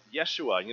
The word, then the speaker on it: Yeshua: More appropriately pronounced Yeshua.